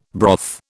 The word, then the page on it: broth: British English